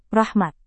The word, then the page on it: rahmat: Indonesian